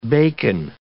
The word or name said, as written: bacon